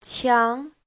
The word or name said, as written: qiang2